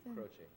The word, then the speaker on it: Croce: I just have to ask you, how do you say your own name? It's spelled C-R-O-C-E. – Croce. – Croce. Mm-hmm. That's right.